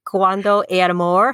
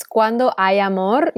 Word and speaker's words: cuando hay amor: Honestly, it's cuando hay amor. You don't pronounce the H, but you really did nail it.